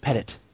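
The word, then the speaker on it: Pettitte: But anyway, the Yankees rotation Cal is going to be CC Sabathia, Andy the ageless one, Petit. Uh, are, actually, I believe it's Pettit. I believe it's pronounced Pettit.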